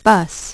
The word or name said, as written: bus